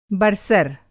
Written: बर्सर

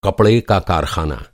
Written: कारख़ाना

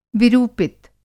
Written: विरूपित

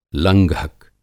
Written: लंघक

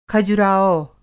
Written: खजुराहो

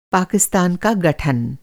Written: गठन

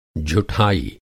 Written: झुठाई

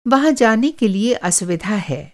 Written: असुविधा

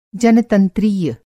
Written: जनतंत्रीय

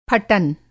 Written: फटन

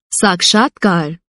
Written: साक्षात्कार

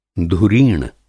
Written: धुरीण

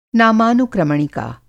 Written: नामानुक्रमणिका

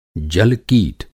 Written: जल-कीट

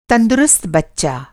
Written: तंदुरुस्त

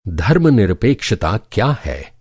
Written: धर्मनिरपेक्षता

